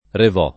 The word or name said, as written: Revò